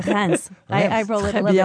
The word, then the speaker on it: Reims: R-E-I-M-S. This is one of those towns that are hard to pronounce. It sort of rhymes rhymes with France. – Yes. – But it's spelled like Reims. The average traveler would look at that and go, Reims, but it's Reims. You've got a wonderful ability to say it. How would you say it in French? – Reims. – Reims. – And then you have to pronounce the S at the end, which usually we don't.